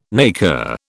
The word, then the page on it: naker: British English